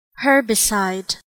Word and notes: herbicide: American English

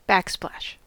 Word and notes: backsplash: American English

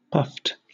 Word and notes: puffed: British English

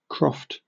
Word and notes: croft: British English

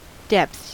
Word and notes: depths: American English

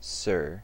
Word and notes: sir: American English

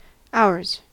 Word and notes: ours: American English

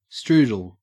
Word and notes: strudel: Australian English

